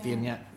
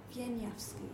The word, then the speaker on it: Wieniawski: I can't pronounce his name. – Wieniawski. Wieniawski. Yeah, Wieniawski. – Wieniawski. – Yeah, that's really good. Right.